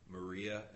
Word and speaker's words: Maria: Maria, M-A-R-I-A.